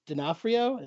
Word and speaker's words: D'Onofrio: You know what's funny is Vincent D'Onofrio, that's how you pronounce his last name.